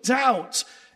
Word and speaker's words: doubt: So unbelief is the willful refusal to believe, but doubt is the inner uncertainty. The word doubt has its roots in the word double.